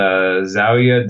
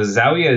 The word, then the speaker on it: Zawiya: Is a place called Zawiya de Fran. That's going to be a weird, so I'll spell that out for people. So Zawiya is usually spelled like Z-A-W-I-Y-A is one way to spell it in English, sorry.